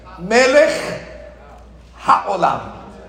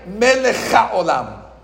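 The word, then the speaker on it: Melech HaOlam (מלך העולם): Boys and girls, before I explain to you what Melech HaOlam means, we have to say it correctly. Melech HaOlam. A lot of times we swallow the hair. So you say Melech HaOlam. It's not HaOlam. The Chaf is not the first word of HaOlam.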